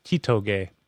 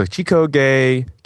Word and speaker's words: Chitoge: Chigose? – I can never pronounce her name correctly. – I thought it was Chitoge. – Yeah, so Chitoge Kisaragi.